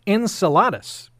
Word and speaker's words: Enceladus: But it is spelled E-N-C-E-L-A-D-U-S. And my guess is most people are going to say Enceladus. – Mm-hmm. – That is not correct. It's Enceladus. Enceladus, sixth moon around Saturn. You had one, Cillian Murphy.